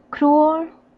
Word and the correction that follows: cruel: Almost. The pronunciation is cruel. Cruel. It's the same word in Spanish, right? Cruel. We just pronounce it differently.